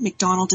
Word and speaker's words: McDonald: McDonald is spelled M-C-D-O-N-A-L-D.